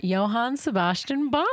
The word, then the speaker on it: Johann Sebastian Bach: And I do know how to pronounce this one because I'm not that dumb, and it's Johann Sebastian Bach. – Yeah, it is.